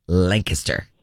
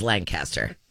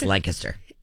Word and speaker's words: Lancaster: And, sir, it is pronounced Lancaster. – Okay. – Thank you. – In other places, it is Lancaster. But in Pennsylvania, it's Lancaster.